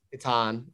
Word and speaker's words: Hiten: I might butcher his name, by Hiten Samtani or Hiten, H-I-T-A-N. – Oh, Hiten? – Hiten, yes. – Hiten, yeah.